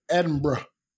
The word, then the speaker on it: Edinburgh: And I was actually talking to my friend Raluca, who lives in France, about going to Edinburgh. – Yeah. That's how you say it.